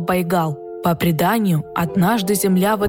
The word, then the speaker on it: Байгал: И, естественно, это слово Байкал, оно трансформировалось в бурят-монгольское произношение как Байгал.